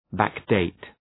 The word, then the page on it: backdate: ,bæk’deıt